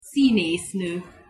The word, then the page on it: szineszno: Hungarian